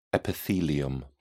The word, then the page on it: epithelium: British English